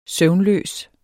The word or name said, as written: søvnløs